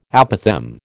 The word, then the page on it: apothegm: American English